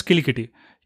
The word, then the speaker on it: Kilikiti: You heard me right. I'm not talking about Kiliki, which is the language from Bahubali, or, you know, mispronouncing cricket. It is Kilikiti.